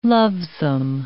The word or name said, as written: lovesome